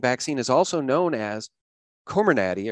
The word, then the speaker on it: Comirnaty: Comirnaty? Is that how you say it? – That's how I say it. – Does anybody know? – I've heard Comirnaty, something that I call it.